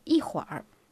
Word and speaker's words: 一会儿: Et un petit moment se dit en chinois yihuar.